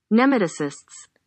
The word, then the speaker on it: nematocysts: Nematocysts.